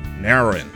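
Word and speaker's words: Naoero: The country's international code will change from NRU to NRO, and its people will be known as De Nauru instead of Nauruan. Commonly known abroad by the pronunciation of Nauru, the new name is spoken Nauru.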